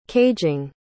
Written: caging

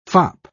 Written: fop

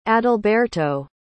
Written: adalberto

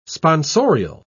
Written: sponsorial

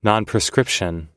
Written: nonprescription

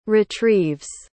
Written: retrieves

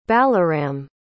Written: balaram

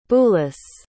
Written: Boulus

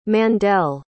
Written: Mandell

